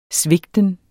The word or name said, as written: svigten